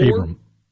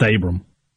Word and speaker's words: Abram: Jalen Aborum, a corner from Oak Grove, Gabriel Moore. – Abram. – Huh? – He's just Abram. – I put an extra syllable in there. – Yeah, put an extra O in there.